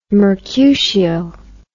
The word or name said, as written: Mercutio